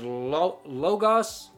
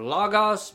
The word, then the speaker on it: logos: You see, so the Word, this word in Greek is logos or logos. People pronounce it differently.